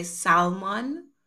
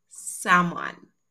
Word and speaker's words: salmon: Now, a lot of people will say salmon, but because there's an L, but the L is actually silent. So it's pronounced salmon.